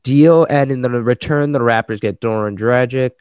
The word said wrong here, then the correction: Goran: Goran, Gor- Goran, Dragic.